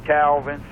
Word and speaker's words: Calvin: You can find me under my real name, Carrie, C-A-R-Y, Calvin, C-A-L-V-I-N, or under Game Changer, and it's spelled the unique way, GAIMCHNG3R.